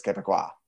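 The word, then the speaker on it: Québécois: I had to look up the pronunciation for this podcast. It is Quebecois.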